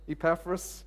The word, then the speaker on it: Epaphras: I say Epiphras. I think uh, Ephras, Epaphras, Epaphras, okay, Epaphras.